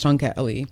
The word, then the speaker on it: Tongkat Ali: and I always say not Muhammad Ali, it's Tongat Ali, and that's spelled T-O-N-G-K-A-T-A-L-I.